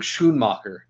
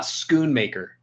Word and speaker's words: Schoonmaker: Like, it's Luke Schoonmaker. Like, it's not Schoonmaker. Can we stop calling the dude Schoonmaker? Jesus.